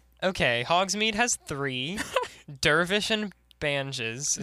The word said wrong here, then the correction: Banges: Is bangs. – That how you say it? – Dervish and Bangs.